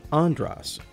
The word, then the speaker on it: Andras: I've spent my entire adult life trying to help people pronounce my name correctly, and the twin ah vowel tones in mantra are the same as the twin tones in my name, Andras.